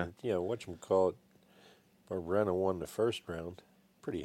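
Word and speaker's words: Barberena: I'm going to butcher his name. Barbarena, right? – That sounds right to me.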